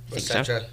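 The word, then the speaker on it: Osetra: is that how you say it? Ostera caviar? – Ostera. Either one. I've heard it both ways.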